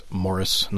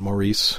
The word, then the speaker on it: Maurice: Speaking of, I think it's actually pronounced Morris, not Maurice, the Emo Forster novel. So just don't write in. We got it. We got it. I made a mistake.